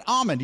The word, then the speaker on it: almond: But the palm, the palm of your hand, palm tree, an almond. You don't pronounce the L.